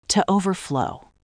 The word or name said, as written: to overflow